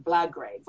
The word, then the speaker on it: Blagrave: I mean, there's one, the Belgrave, I think you pronounce his name, Blagrave. – Blagrave.